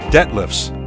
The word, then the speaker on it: Dethlefs: At number 5 is a song called Daydream by Patrick Dethlefs, and he spells that D E T H L E Fs. The H is silent.